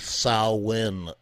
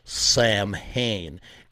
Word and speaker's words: Samhain: that's not how you pronounce the name of the band. It's actually Samhain. It's not Samhain. Even the band, even Glenn Danzig, he pronounces the name of his band wrong. It's actually Samhain, okay? It's the sow like a cow and then in, like you're going in a door, okay?